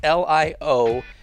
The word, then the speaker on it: Lionkness: This is an artist by the name of Lionkness, but there's a silent K in there, so it's Lionkness.